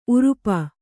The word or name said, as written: ಊರಪ್ಪ